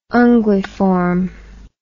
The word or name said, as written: unguiform